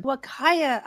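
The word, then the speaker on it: Huacaya: Wakaya alpacas. – Wakaya alpaca females.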